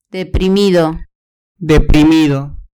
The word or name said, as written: deprimido